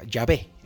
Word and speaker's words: Yahvé: cómo se pronunciaba. Yahvé es el término correcto.